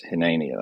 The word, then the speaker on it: hineni: Uh, it's, it's the word hineni. Um, if you were to write it out, it'd be H I N E N I, pronounced Hineni.